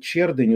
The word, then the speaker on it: Чердынь: Вот, мне пишут Чердынь, ударение на первом слоге. Мосин, спасибо вам. Значит, смотрите, вот трагедия. А? В городе Чердынь Пермского края его население, внимание, 5000 человек.